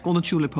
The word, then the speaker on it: Golden Tulip: Dat de vierde werd Marten van Haren gebeld door een receptioniste van een Golden Tulip. Zeg ik het goed? Ja, Golden Tulip Hotel.